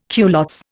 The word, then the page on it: culottes: Indian